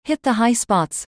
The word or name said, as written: hit the high spots